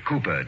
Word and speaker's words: Cowper: William Cowper. – Cooper, darling. It is spelled Cowper, but pronounced Cooper.